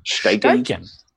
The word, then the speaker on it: Steichen: I can't even pronounce that guy's name. – Steichen. – Guy, I'm going to go Steichen.